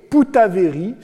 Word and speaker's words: Bougainville: Il devient le taillot de Bougainville, avec lequel il échange son nom, se faisant appeler désormais Poutaveri, ce qui est la prononciation à la tahitienne de Bougainville.